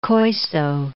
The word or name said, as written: Koiso